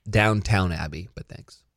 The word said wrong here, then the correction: Downton: No, there's no W. Mm. Well, there's one. – Well, British people don't pronounce the W, so it's Downtown Abbey. Okay, whatever. Never seen it. – No, I'm fucking with you. It's Downton Abbey.